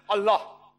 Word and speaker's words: Allah: The right pronunciation is Allah.